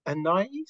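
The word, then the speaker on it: Anaïs: And it's good to see that the process, obviously, they is it, do you pronounce her name Anais? Did you say? I thought it was Anais. – I've always said Anais. – Anais. – They say, I think that's like the French way.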